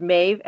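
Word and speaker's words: Maebh: Her name is Maeve, and it's spelled the correct way.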